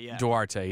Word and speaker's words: Duarte: And for the Grizzlies right now, he's got them taking Chris, I think it's Duarte. – Duarte, yeah.